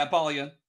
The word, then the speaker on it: Apollyon: Is it Apollyon? How do I say it? – Yeah, Apollyon.